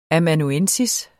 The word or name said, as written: amanuensis